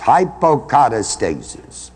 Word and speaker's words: hypocatastasis: It's spelled H-Y-P-O. Then put a slash behind the O. And kata. C-A-T-A, put another slash. And then you've got stasis, hypocatastasis.